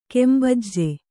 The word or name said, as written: ಗುಂಬಜ್